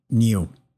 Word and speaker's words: NIU: Well, I think the company is pronounced NIU. It's N-I-U, NIU Technologies.